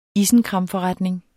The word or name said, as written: ISENKRAMFORRETNING